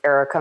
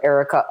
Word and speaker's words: Erica: It's Erica Mather or Erica underscore Mather.